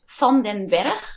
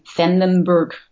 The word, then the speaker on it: Van den Berg: Yeah, it's Van den Berg. It's Dutch, but I think in American it will be Van den Berg. It sounds pretty yeah.